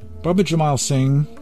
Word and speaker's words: Baba Jaimal Singh: Baba Jaimal Singh, as I pronounce his name, it could be also Baba Jaimal or Jaimal Singh, I like and go with my habitual pronunciation of Baba Jaimal Singh.